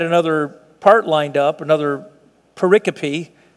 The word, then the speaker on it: pericope: That's just a word like the word periscope without the S. It means like a passage of Scripture, pericope. But it's pronounced that way without the S, like periscope. Same, just get rid of the S, pericope.